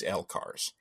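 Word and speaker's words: LCARS: and he said there is no canon pronunciation of L-cars. But he uses L-cars.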